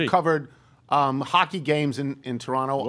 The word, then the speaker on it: Toronto: By the way, didn't we learn from the movie Argo that it's pronounced Toronto? – Toronto.